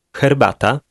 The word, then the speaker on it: herbata: The first letter of number 7 sounds like the English letter H, but it's pronounced a little more forcefully. Listen and repeat. – Herbata.